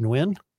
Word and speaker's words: Nguyen: Nguyen, is it Nguyen? – Nguyen. Yeah.